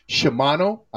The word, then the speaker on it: Shimano: Steptoe was saying that that what I was talking about, the same people that make gears for bikes, Shimano, I think it's that's yep.